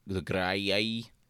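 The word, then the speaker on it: Graeae: Apparently Medusa is actually identified with Libya, but there's also they both had dealings with the three witches, the Graiai, that's how it's pronounced, who shared the eye.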